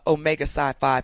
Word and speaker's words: Omega Psi Phi: the members of Omega Psi Phi Fraternity Incorporated.